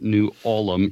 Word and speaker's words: New Ulm: There's the town of New Ulm, which everyone around, all the old timers, they pronounce it New Ulm, even though it's spelled U-L-M. So I have no idea why that is.